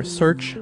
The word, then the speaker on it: Searx: So the first one is Search, or Searx, however you want to pronounce it. It is spelled S-E-A-R-X. This one's cool. I like this a lot. Searx, or Search, I think it's probably pronounced Search, is an open source search engine that searches all the other search engines at the same time.